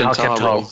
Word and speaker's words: Alcantara: This is how you pronounce Alcantara. It's spelled Al-can-ta-ra. He's put in brackets. And then put Al-big-al, can-like-can-ta-ra.